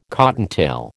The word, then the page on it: cottontail: American English